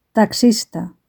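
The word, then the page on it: taxista: Spanish (Spain)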